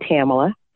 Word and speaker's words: Tamala: You know, I love the way that you pronounce my name, but it's not quite that exotic. It's just plain Tamala.